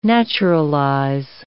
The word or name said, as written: naturalize